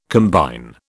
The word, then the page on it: combine: British English